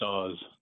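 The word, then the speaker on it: Os: But well, pronounced Oz with a Z.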